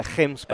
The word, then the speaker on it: Gemsbok: We call it a Gemsbok. – A Gemsbok, and that's the Dutch influence, right? – Yeah, that's the Dutch pronunciation of the G.